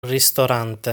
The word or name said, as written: Ristorante